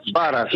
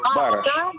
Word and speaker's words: Бараш: Бараш. Или Бараш. Ударение на первый слог. Бараш. – Нет, Б-А-Р-А-Ш, правильно? – Бараш. Бараш, да. А на иврите Б-Т-Р-Ш-И-М.